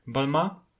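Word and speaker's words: Balmain: Wiem, że zapis tego wyrazu prowokuje, aby na końcu zrobić nosowe o, jednak nic bardziej mylnego. Balma to po prostu balma.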